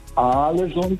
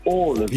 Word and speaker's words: Ålesund: Also das heißt nicht Ahlesund, das heißt Ålesund.